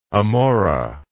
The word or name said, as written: amora